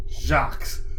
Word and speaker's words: Jacques: It's spelled J-A-C-Q-U-E-S. Jacques.